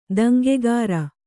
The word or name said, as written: ದಂಗೆಗಾರ